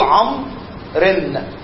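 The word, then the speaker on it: عمرو: ما هو إحنا قلنا إن الواو حرف زائد، أنت لما بتكسر بتقول عمرن، أنت كسرت إيه؟ الره ولا الواو؟ شوف محمد يقول لي أنا ما نطقتش واو أساسا، فأنت ما نطقتش الواو هتشكلها إزاي؟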